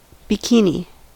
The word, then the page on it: bikini: American English